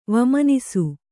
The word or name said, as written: ವಮನಿಸು